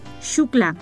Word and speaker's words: Xuclà: Calle Xuclà. Se escribe XUCLA, Xuclà.